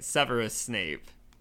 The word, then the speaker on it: Severus Snape: Severus Snape.